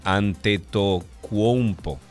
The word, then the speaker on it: Antetokounmpo: Ante Tocuompo. ¿Lo dije bien? Ok, bueno, Antetokounmpo, así mismo.